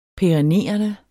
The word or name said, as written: perennerende